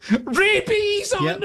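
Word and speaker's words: Bease: So Bees, it's B-E-A-S-E, but it's pronounced Bees.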